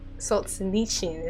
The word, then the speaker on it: Solzhenitsyn: Solzhenitsyn is how I've heard people say it. Alexander Solzhenitsyn. Oh god. This is a thing. Okay, someone just said Solzhenitsyn. Oh god. What? Alexander Solzhenitsyn.